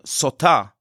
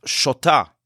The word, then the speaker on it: sotah: The Talmud points out that this word for this woman, sotah, can also be pronounced shota, which means foolish.